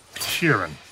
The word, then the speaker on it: Sheeran: Frank Sheeran.